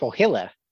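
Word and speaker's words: Põhjala: also, fun fact, it's pronounced Pohilla. Pohilla. Yeah, I just watched a video.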